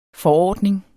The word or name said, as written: forordning